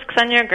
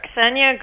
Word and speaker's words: Ksenya: Ksenia Gerstein. – How do you spell that? – K-S-E-N-Y-A. – Wow, so you have to get that K in there quick before you move on to the S.